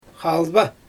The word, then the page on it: xalba: Xyzyl